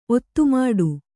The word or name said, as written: ಒತ್ತುಮಾಡು